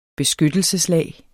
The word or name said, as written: beskyttelseslag